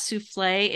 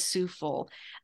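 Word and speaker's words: soufflé: It's not souffle, it is souffle.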